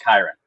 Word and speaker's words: Kieran: Um, uh, just just to let you know, it's uh, pronounced Kyron.